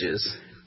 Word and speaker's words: Pendages: Pendages. – How do you spell that? – P-E-N-D-A-G-E-S.